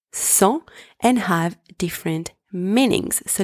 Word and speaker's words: cent: First one, cent, spelled C-E-N-T, means 100. In this word, the letter C is pronounced S. The letters E and N together are pronounced en, and the final consonant T is silent. Second word, sang, spelled S-A-N-G, means blood.